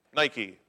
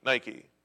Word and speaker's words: Nike: You would pronounce it Nike, Nike. Victory, victory. And he dies, which proves that running kills.